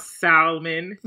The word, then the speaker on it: salmon: Give me a fucking Wild Alaskan salmon, which I'm pretty sure a lot of folks here probably pronounce salmon or pronounce the L as shit.